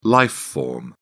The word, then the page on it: life form: British English